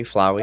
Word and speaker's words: Flowey: Flowey? – Flowey. – I assume it's like a flower.